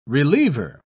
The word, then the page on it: reliever: British English